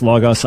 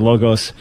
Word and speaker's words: Logos: He's pronouncing it correctly as Logos. I keep saying Logos.